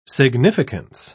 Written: significance

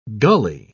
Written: gully

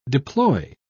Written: deploy

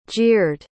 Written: jeered

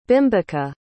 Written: bimbika

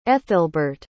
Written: ethelbert

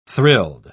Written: thrilled

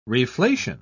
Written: reflation

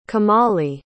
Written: komali

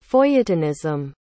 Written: feuilletonism

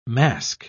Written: masque

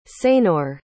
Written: seignour

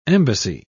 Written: embassy

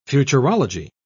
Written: futurology

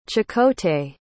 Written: chakotay

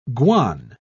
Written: guan

